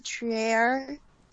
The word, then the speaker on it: Trier: You know, it can be Trier or Trier. I pronounce it Trier.